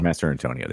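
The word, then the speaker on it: Mastrantonio: This was Mary Elizabeth Mastriano. I can't say her name. Master Antonio. – Master Antonio. There you go.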